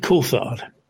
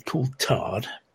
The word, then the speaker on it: Coulthard: David Coulthard, um, name is regularly mispronounced as David Coulthard.